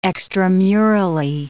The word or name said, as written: extramurally